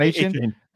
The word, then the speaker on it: Achane: Devon Achane? – Achane.